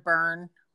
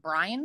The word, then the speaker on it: Byrne: It was a book by Rhonda Byrne. Is that right? I don't know how to say her last name. Brian? – I think it's Byrne. – Yeah, Byrne.